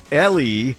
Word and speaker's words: Eli: It is spelled E-L-I, but it is pronounced L-E Savitt, Washtenaw County Prosecutor, the Democrat candidate for Attorney General.